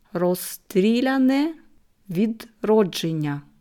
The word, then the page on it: Rosstriljane widrodschennja: slow